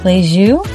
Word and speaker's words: Lisieux: Today we're going to talk about St. Therese Lisieux, right? Is that how you say it? – Yeah, that's how I wrote Lisieux, so I knew how to say it.